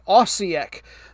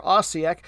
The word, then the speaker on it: Osijek: O-S-I-J-E-K. Osijek.